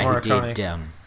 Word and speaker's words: Morricone: How do you say that guy's name? – Aricone? The Sergio, composer? – No. – Oh, Ennio Morricone.